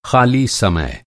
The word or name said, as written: समय